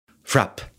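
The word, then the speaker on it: FRAPH: They didn't pronounce the H, and there was a reason for that. Because frappe, in French, frapper, you smack somebody.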